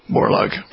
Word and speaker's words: Borlaug: All right, so this film is about this fellow Norman Borlaug. Am I pronouncing it right? – That's right, yeah, Borlaug.